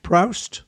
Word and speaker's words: Proust: Proust? Proust? – There you go.